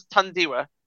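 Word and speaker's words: Thandiwe: Hey, I was just, hey, she wanted to be addressed as Tandiwa, so I'm addressing her as Tandiwa.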